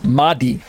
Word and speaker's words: Mahdi: Remember, this word Mahdi can be also seen in, if you do internet searches, Mehdi, or you can hear people just pronounce it as Mahdi. They don't make the H very breathy.